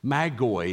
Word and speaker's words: Magoi: Magi, Magoi is how it's pronounced in the original language, traditionally wise men.